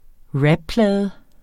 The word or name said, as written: rapplade